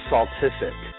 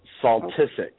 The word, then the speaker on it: Saltisic: Yes, Okay, my last name, it's Saltisic. So it's like Saltisic.